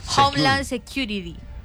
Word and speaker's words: Homeland Security: Homeland Security.